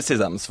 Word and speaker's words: CESAMS: C'est le CESAMS, voilà. – On prononce comme ça.